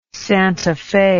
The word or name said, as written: Santa Fe